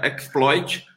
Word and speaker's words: xploit: Quem não quem não, quem está no podcast, né, não, não tá vendo o link aqui, é só digitar trailer xploit, x-p-l-o-i-t, xploit, que se pronuncia exploit, né?